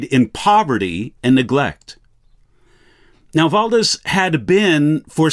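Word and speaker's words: Valdes: Now, Peter Valdez, or you'll also see his name often depicted as Valdo, W-A-L-D-O, but it wasn't pronounced Waldo, most likely Valdez.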